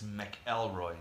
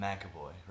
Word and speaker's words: McElroy: It's McElroy, not McAvoy, right? – Cause no, I was told his last name was pronounced McElroy. – McElroy? – Yeah. – McElroy? – Yeah, McElroy.